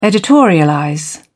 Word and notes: editorialize: British English

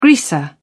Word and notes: greaser: British English

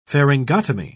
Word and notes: pharyngotomy: British English

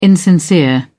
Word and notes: insincere: British English

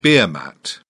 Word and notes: beer mat: British English

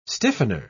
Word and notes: stiffener: British English

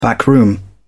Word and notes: backroom: British English

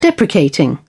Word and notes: deprecating: British English

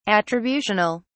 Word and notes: attributional: British English

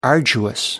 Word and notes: arduous: American English

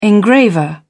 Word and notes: engraver: British English

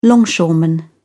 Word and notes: longshoreman: British English

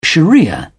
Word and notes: sharia: British English

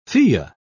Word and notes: fille: British English

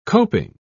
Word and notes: coping: British English

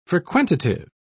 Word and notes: frequentative: British English

